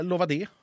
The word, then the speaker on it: Lovad: Eh, Lova det, med andra ord. – Lova det! – Men det ska uttalas Lovad.